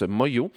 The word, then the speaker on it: Manglo: Ça s'écrit Manglo, mais ça se prononce Moyo.